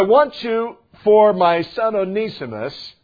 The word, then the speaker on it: Onesimus: The word Onesimus, the name Onesimus, Onesimus is how you pronounce it, if I could continue to do that consistently, means profitable.